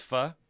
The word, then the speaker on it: pho: Yeah, even though it's pho. I don't want to be that guy, but proper pronunciation is important.